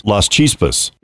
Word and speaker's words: Las Chispas: And you have some pretty significant additional drill results worth talking at, and I believe I pronounced this, Las Chispas. – That's correct.